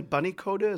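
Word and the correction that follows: Bunyodkor: Is that Bunyot the name of the team? – There we go. Bunyot Kor.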